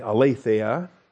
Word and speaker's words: Aletheia: Aletheia, of course, is the noun. Aletheia. Where's the accent on the A? Aletheia. Okay, number 225 in the Strong's Concordance. That's A-L-E-T-H-E-I-A.